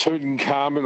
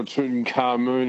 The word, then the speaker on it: Tutankhamun: It's Tutankhamun or Tutankhamun, you can pronounce either way.